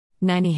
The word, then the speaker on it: Nynnie: Nynnie Hammond rhymes with shiny, not skinny,